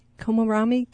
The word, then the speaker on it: Komoromi: Komoromi? K-O-M-O-R-O-M-I.